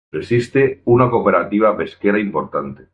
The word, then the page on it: cooperativa: spanisch